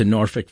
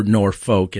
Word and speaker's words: Norfolk: And my mom rode with me to Norfolk, Virginia, or Norfolk, as the Midwesterners would pronounce it.